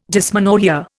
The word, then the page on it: dysmenorrhoea: Indian